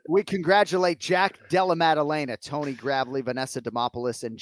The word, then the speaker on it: Jack Della Maddalena: Jack Della Maddalena, Vanessa Demopoulos, Jasmine Jazz Divisius.